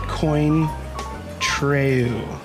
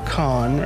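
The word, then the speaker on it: Cointreau: Well, it's a tricky word because it looks like it's, you know, phonetically, it looks like it's coin treu. But it's pronounced K-A-A-N, so con, or yeah, con, I guess.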